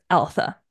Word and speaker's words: Altha: I looked it up and I'm pretty sure it's Alpha. Okay. – It is exactly pronounced exactly how it sounds, even though it's weird.